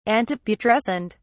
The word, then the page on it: antiputrescent: British English